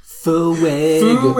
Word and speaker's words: Fuego: And one of them is the pronunciation of fuego. – Fuego. – Fuego. It's three syllables. It's not like, you know, Eleni Ferreira's Fuego, which is very clear too. There's three syllables in Alejandro. This is Fuego.